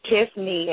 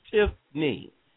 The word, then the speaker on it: Tiffney: Yes, it's Tiffney, and it's actually spelled T-I-F-F-N-E-Y. – Okay, T-I-F-F-N-E-Y. So it's two syllables, right. – Tiffney, not Tiffany. – Tiffney, right. No, it's not Tiffany. It's just Tiffney.